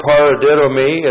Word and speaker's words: paradidomi: The verb paradidomi is a compound verb. Looks like that in the Greek. P-A-R-A-D-I-D, long O-M-I. Paradidomi.